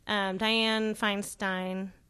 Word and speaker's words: Dianne Feinstein: Dianne Feinstein, am I saying that correctly? – Yeah.